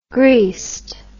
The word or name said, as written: greased